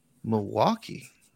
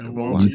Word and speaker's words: Milwaukee: And we have our great guests coming out of Milwaukee. – All right. – I said that right, right? – Milwaukee.